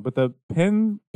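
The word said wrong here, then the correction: Pijn: collaboration? – Oh, Pine. Yeah, Pine. I'm fucking way off. – But yeah, they get called Pigeon a lot. – Pigeon. – But no, officially it's not Danish, it's Dutch is the word I'm looking for. Yeah, it's pronounced Pine.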